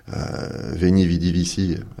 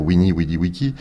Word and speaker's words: Veni, vidi, vici: Par exemple, quand César dit Veni, vidi, vici, avec une grosse erreur de prononciation du latin, puisqu'il devrait dire Wini, widi, wiki, ah tiens, c'est pas mal ça, je la replacerai.